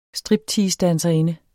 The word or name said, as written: stripteasedanserinde